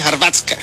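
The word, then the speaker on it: Hrvatska: Yo creo que tiene que ver Chile con Chile y Harvatsku Croacia, porque se dice así en Croacia, en croata se dice Harvatska.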